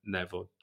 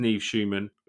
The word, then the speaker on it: Nev Schulman: It's hosted by a guy called, I think it's Neve Schumann, but his first name is spelled N-E-V, so I've normally mispronounced it as Neville. But his name is Neve Schumann, who runs this show called Catfish.